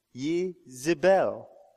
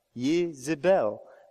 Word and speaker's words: Yezebel: It's Yezebel. Yezebel. And in English, we take that ye, make it a je, and we say Jezebel.